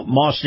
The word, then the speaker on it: Maus: And by the way, this portion of the program brought to you by Moss Nissan. Moss is spelled M-A-U-S.